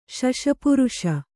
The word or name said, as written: ಶಶಪುರುಷ